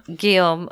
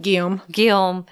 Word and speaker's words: Guillaume: Guillaume.